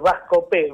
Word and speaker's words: Bascopé: Es Bascopé, con acento en la E.